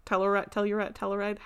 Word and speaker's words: Telluride: Other than, it's not, like, super far from Telluride. Telluride? How do you say it? – Telluride.